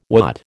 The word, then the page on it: watt: American English